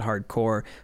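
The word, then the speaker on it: Hard Corps: Contra Hardcore, at least in the US we would pronounce that hardcore, C-O-R-P-S, that's a fun way to say that title.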